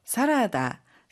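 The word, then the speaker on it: 사라다 (サラダ): 샐러드는 사라다라고 하는데 모음 전체가 다르게 발음되니 주의해 주세요.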